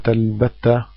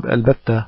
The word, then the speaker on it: البتة: أن ينطقوا كلمة البتة بهمزة قطع، البتة بهمزة قطع إذا قالوها في درج الكلام ووصله، وهذا خطأ شائع ليس له مستند إلا الشيوع على ألسنة بعض العلماء المتأخرين، غلطا متوارثا بينهم. والصواب وصواب في نطقها بهمزة الوصل إذا جاءت درجا موصولة بما قبلها في النطق،